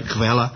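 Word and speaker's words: Quelle: And by the way, Q stands for Kvela. Is that right? Do I pronounce it right? Kvela? – Kvela.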